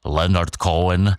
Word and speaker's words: Leonard Cohen: Správně bych měl vyslovovat Leonard Cohen, ale nebudu hledat detaily, na Moravě nepodstatné.